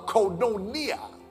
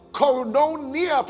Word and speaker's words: koinonia: That word koinonia from the Greek is from whence the word coin is gotten from. It's spelled K-O-I-N, but coin is C-O-I-N.